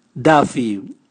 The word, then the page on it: davi: Raga